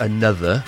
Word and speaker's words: Anotr: Another featuring Fristofil Ultra, Talk To You. Always careful how to pronounce new bands and new artists, because sometimes you just can't be sure. That's spelled A-N-O-T-R, but pronounced another.